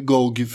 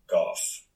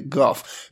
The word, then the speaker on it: Gough: G-O-U-G-H. – Goff. – Ju mer jag uttalar det här namnet så får jag känslan av att det kanske inte är Gogg. Det är inte fan Gogg vi pratar om här utan det kanske är Goff. – Ja, det kan nog vara en bra gissning. Det är G-O-U-G-H.